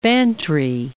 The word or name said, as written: Bantry Bay